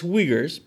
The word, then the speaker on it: Uyghurs: Is it Uyghurs? – Huh? – Yeah. – Am I saying it wrong? – Say it faster. – it's Uyghurs, but yeah.